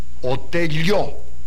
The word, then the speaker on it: Otello: Let us take Othello, shall we? Othello. – Yes, but already you pronounce in the right way, because myself like it the best, Otello. – Ah, Otello. Very well.